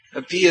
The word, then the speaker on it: api: Api is a tiny little Sanskrit word, only two syllables. Api. Two syllables.